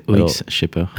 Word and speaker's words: Shaper: Tout à fait, OX shepherd.